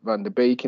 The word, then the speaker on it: Van de Beek: I'd just take him out of the equation, and I'd stick Van de Beek in there.